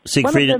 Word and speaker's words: Siegfried: And is it Siegfried and Roy, or is that how you pronounce his? – Siegfried and Roy, yeah.